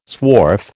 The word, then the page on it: swarf: American English